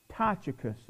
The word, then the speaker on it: Tychicus: Now, I'm not going to use all 17 or we'd be here a long time, but look at, he mentions the name Tychicus.